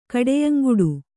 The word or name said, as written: ಕಡೆಯಂಗುಡು